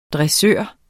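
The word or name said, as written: DRESSØREN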